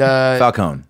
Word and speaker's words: Falcone: And Falcone. – Yeah. I pronounce the E. That's how the dwarves say it.